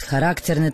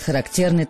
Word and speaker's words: характерный: Надо сказать, что по-разному ставят ударение. Кто-то говорит «характерный танец», кто-то говорит «характерный танец». Я думаю, что все-таки правильнее говорить «характерный танец».